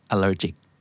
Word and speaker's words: allergic: allergy กับ allergic นะครับออกเสียงไม่เหมือนกันนะครับ allergy allergic Do you have any allergies?